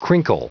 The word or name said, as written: crinkle